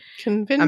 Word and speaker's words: convincere: I'm probably not pronouncing those right, but convincere. I mean, it's not that. Convincere. Convincere. Latin's pronounced weird. We're Catholic, so we do like the Italianization of Latin, which sounds prettier.